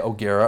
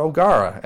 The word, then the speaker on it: O'Gara: So every time I go on a radio show, people ask me, or they'll just pronounce it their own way that they want. They'll say O'Gara or O'Gara.